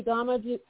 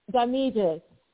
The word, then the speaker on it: Ngamije: But today, I am speaking with someone like way over there in Africa, in Namibia, author Remy Ngamije. Am I pronouncing your name correctly, Remy? – Ngamije. Ngamije. – Ngamije. Okay, so see, yeah. I got corrected.